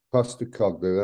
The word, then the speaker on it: Postecoglou: Postecoglou, that's it.